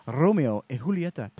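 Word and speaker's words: Romeo y Julieta: JR's inventory ranges from everyday bundled cigars to incredibly high-end boxes, including the brand new exclusive Romeo e Julieta Passion.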